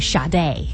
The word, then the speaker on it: Sade: 没错，送上，哎呀，这个人名也是 spelled as S-A-D-E, 但是呢，应该读成 Sade.